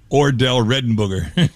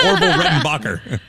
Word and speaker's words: Orville Redenbacher: She used to call it Ordell Redenbooger. She'd be like, do you want some Ordell Redenbooger popcorn? I'm like, Grandma, that's Ordell. And then I couldn't even pronounce it properly. I'm like, it's Orville Redenbacher.